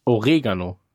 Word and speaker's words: Oregano: Ähm, übrigens gerade nachgeguckt, der Duden sagt, die Betonung bei Oregano liegt auf dem E. Service Hinweis. Ah. – Oregano.